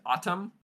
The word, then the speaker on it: Atum: His name is Atum, I believe is how you pronounce it. It's A-T-U-M.